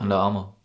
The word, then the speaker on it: Under Armour: Under Armour.